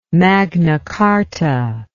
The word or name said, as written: magna carta